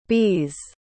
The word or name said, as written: bees